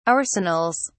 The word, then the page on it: arsenals: British English